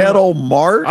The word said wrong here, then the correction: Ketel Marte: I looked up the pronunciation. I guess it was wrong. – Ketel Marte. – Ketel Marte.